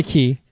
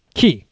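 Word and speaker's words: Cay: So, Castaway Key. – Now, is it Key or K? – Key. It's pronounced Key and it's spelled K.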